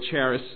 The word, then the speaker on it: charis: It's spelled C-H-A-R-I-S. C-H-A-R-I-S. The C is silent. In the Greek is pronounced charis.